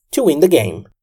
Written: to win the game

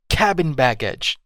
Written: cabin baggage

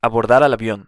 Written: abordar al avion

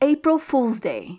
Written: April Fools' Day

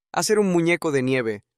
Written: hacer un muneco de nieve

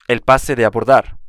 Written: el pase de abordar

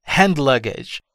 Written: hand luggage

